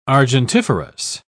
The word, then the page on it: argentiferous: British English